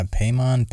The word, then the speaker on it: Paimon: Paimon. Is that how you would pronounce it? – Yeah, Paimon, P-A-I-M-O-N.